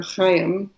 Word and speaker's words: chayim: but it's the word chai or chayim. You've probably heard people say l'chayim, right? To life. And it makes that cha sound at the beginning, which is not always easy to pronounce.